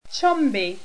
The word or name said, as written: chomby